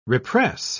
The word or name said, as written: repress